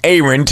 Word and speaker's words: Aaron: It's spelled like Aaron, A-A-R-O-N, pronounced Aaron.